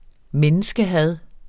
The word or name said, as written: menneskehad